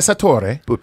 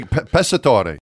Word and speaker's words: Pessatore: No, it's Passatore. – P-A? – Nope. P-E-S-S-A-T-O-R-E. – That's pretty. Passatore.